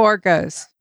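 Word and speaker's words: Borgias: It was directed by Kerry Skoglin, who has 57 directing credits starting in 1994, including 6 episodes of The Borglase, Is that how you say it, by the way? Borglase? – The Borgas. – The Borgas. Okay,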